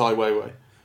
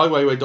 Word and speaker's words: Ai Weiwei: I didn't know how to pronounce his name before. I'd never heard anyone say it authoritatively, but he actually says it in the documentary. So it's Ai Weiwei.